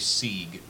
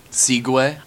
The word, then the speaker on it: Segway: Take a drink whenever Colin pronounces Segway Sieg. – Oh, yeah. – Segway? – I do that a lot. – No, you do that. – For years, he thought it was pronounced Sieg.